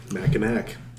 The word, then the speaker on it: Mackinac: Why do they pronounce it Mackinac? – Because that's how you pronounce it. – In what language? – Indian. – It's spelled like Mackinac, right? – Ojibwe. – C-A-C-K. Well, there's Mackinac Island and then there's Mackinac with an A-W.